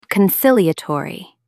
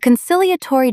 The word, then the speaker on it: conciliatory: Con, sil, e, a, tor, e. – Meaning, peacemaking, soothing, and designed to calm tension. Look at the root reconcile. A conciliatory gesture is an olive branch meant to restore harmony. Stress the second syllable. – Conciliatory. – Say it out loud.